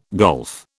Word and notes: golf: British English

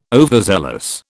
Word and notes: overzealous: British English

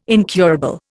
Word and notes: incurable: Indian

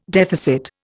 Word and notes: deficit: Indian

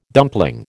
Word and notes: dumpling: American English

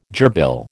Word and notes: gerbil: American English

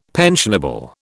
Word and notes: pensionable: British English

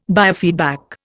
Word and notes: biofeedback: Indian

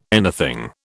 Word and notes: anything: British English

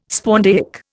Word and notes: spondaic: Indian

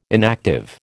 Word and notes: inactive: American English

